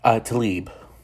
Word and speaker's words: Tlaib: AOC and Democrat Rashida Tlaib. Am I pronouncing that correctly? – Tlaib. – Rashida Tlaib, my apologies, Ms. Representative Tlaib.